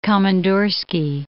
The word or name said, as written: Komandorski Islands